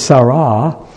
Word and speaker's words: Sarah: And this is Sarah. In Hebrew, they would accent the second syllable.